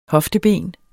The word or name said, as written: hofteben